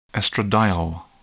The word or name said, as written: estradiol